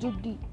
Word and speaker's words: jeudi: In French it is known as Judi. It is J-E-U-D-I, Judi. Judi. It is like it. It's pronounced as Judi by Google.